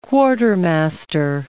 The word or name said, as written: quartermaster